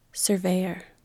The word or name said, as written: surveyor